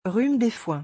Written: rhume des foins

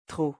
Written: trop

